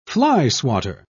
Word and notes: flyswatter: British English

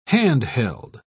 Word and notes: hand-held: British English

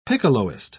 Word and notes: piccoloist: British English

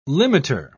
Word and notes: limiter: British English

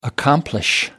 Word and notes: accomplish: American English

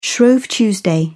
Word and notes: shrove tuesday: British English

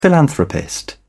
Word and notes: philanthropist: British English

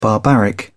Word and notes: barbaric: British English

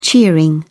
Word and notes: cheering: British English

